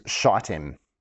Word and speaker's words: Shittim: I'm so glad that the New Living Translation says Acacia Grove, because the actual name of the place is Shittim. It's a long I, but sometimes people mispronounce it, and it sounds like a rude word in church.